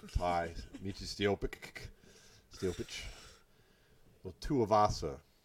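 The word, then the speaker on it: Tai: You know his name's Ty, right? – Ty. Am I saying it wrong? Tia? Ty? Oh, it's just Ty.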